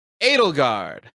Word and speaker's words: Edelgard: Did I say her name right? – You did. Yes. – Yes. – Way to go. – It looks like Edelgard or Edelgard, but apparently it's Edelgard. – Correct.